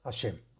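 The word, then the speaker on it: השם: Se deberá meditar el santo nombre de Hei Shin Mem, que se pronuncia Hashem.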